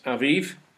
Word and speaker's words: Abib: So that month, which was called Abib, or pronounced Aviv, and later became known after the Babylonian captivity as Nisan, was the start of Israel's religious calendar.